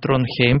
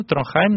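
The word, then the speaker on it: Trondheim: Это Норвегия, это норвежский хор, город Тронхейм, по-моему. Тронхайм, наверное, правильно говорить.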